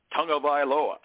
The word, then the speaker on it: Tagovailoa: The one that comes to mind, of course, is Tua Tagovailoa. That's how they say it around here.